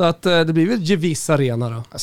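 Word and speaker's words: Gewiss: Eh, och ska man uttala det på italienska med GE, då blir det mjukt G. Då blir det Gevis.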